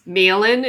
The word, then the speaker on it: Malin: Burning in Water, which became I've been pronouncing the name of the gallery wrong for like years now. It's Malin, is that the case? – Correct, yeah.